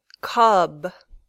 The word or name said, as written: cub